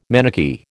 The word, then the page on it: Manichee: American English